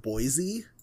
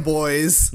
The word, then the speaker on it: Boise: It's pronounced Boise? – Yes. – Yeah, Boise, yeah. Idaho. – Oh, Idaho. – My God. – What? Wait, what is it? – We just weed them boys. – No. – It's boys. – It's Boise.